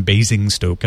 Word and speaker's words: Basingstoke: Anyway, the company's already getting things back up, and by now it sounds like everybody in Basingstoke, as I'm told it is pronounced, has their service back.